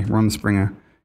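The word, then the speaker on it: Rumspringa: It's one of the Runspringa is that fucking shit the Amish people do. I'm probably saying that wrong. – Runspringa? – Yeah, R-U-N. – My favorite rapper. – Spring-A, Runspringa.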